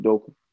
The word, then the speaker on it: Udoka: Udoka.